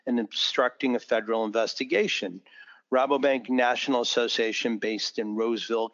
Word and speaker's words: Rabobank: And I guess, do you say Rabobank? Am I saying it correctly? – Rabobank. – Okay.